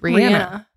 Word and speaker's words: Rihanna: But I think she came out with a statement saying that it's pronounced Rihanna.